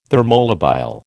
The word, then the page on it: thermolabile: American English